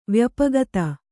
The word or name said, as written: ವ್ಯಾಪಕತೆ